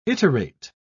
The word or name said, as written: iterate